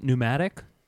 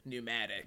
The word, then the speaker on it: pneumatic: The way that we do it for you is that we spell it like your last name, so it's N-E-W-M-A, yes. Pneumatic.